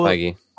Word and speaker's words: Feig: Feige. – No, Feige. Not Kevin Feige. I'm talking about the Bridesmaids, Ghostbusters guy. – I think it's Paul Feig.